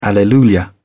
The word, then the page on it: alleluia: British English